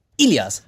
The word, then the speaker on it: Ilias: Ich sage immer Elias, aber das ist natürlich Quatsch. Ilias, mit Betonung auf dem ersten I.